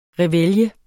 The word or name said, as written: reveille